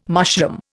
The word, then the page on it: mushroom: Indian